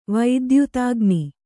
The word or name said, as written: ವೈದ್ಯುತಾಗ್ನಿ